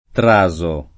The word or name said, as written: Traso